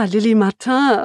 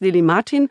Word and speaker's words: Lily Martin: Also als du gesagt hast, du bringst dieses Buch mit, habe ich, hab ich so gedacht, so, ah, Lili Martin und habe das so ganz französisch, französisch ausgesprochen. Du sagst jetzt Lili Martin, also was kannst du uns über die Autorin erzählen? Das klingt nicht so französisch.